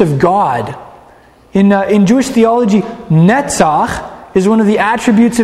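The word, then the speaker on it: Netzach (נצח): The Hebrew verb for overcoming is Netzach. Everybody say Netzach. It's spelled Nun Tzadi Chet. Netzach. If you want to say an overcomer, you say Menatzeach.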